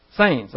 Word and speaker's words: Saenz: Jonathan Sienz, I hope I'm pronouncing it right. His last name is spelled S-A-E-N-Z. Sienz? Sienz, okay.